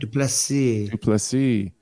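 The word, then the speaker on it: du Plessis: Dude, Derek Brunson, Duplessis, is that how you say it? – Duplessis. What a fight.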